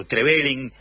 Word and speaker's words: Trevelin: Bueno, ahí obviamente hace un tiempo estuve ahí por Trevelin, así se pronuncia, ¿no? Porque tengo una dificultad. – Sí.